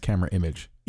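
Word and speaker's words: Camerimage: You keep telling me that it's actually pronounced Camera Image. – Yeah. Everyone from Poland is part of the festival, calls it Camera Image.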